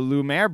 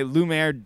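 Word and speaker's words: Lumière: Lumiere. Stop. Can you not say his name? – You say Lumiere. – Lumiere. – It's Lumiere. – Lumiere. Lumiere. – There you go.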